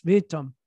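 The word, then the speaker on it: witam: It's actually spelled with a W, but yes, vitam.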